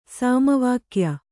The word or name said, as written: ಸಮವಾಕ್ಯ